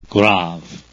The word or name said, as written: graves